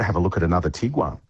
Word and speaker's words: Tiguan: Afternoon, I'd like to replace my Tiguan with a, is that how you say it?